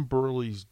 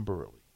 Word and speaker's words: Burleigh: But there's a song called Tom Burley's Dead. And Harrison Burley is spelled B-U-R-L-E-I-G-H. Tom Burley. – You know, the Irish way to spell Burley.